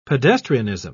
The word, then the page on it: pedestrianism: British English